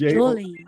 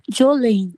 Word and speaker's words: Joelye: It's Jolene. J-O-E-L-Y-E. – And how do you pronounce that? – Jolene. Jolene.